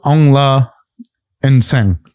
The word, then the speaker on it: Aung Nsang: He's the 2018 International Fighter of the Year, Mr. Aung Nsang.